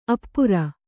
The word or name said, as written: ಆಪೂರ